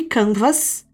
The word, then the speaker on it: Canva: Conversando com outros membros da equipe, um dos caras do time era francês e falou que Canvas se pronunciava Canva no seu idioma, sem pronunciar o S.